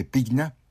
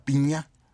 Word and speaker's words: Pigna: Otro más, miren, una novela de otro cariz, de otro tipo distinto, argentina, la escribe un gran historiador, Felipe Pigna, o Piña, con G, Pigna se escribe, pero creo que se pronuncia Piña, y se llama Conspiración en Londres.